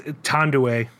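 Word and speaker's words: Thandiwe: Is it Thandaway Newton? It's Tondaway. Tondaway Newton.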